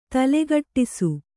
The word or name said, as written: ತಲೆಕೆಡಿಸು